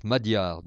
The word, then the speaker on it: magyar: on prononce madyar d'ailleurs,